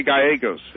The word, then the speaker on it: Gallegos: And with us, the campaign manager for Friends of Paul Viegos. Am I pronouncing that right? – We say Gallegos. – It's Gallegos. – G-A-L-L-E-G-O-S. – Oh, there you go. I wrote it down, Gallegos.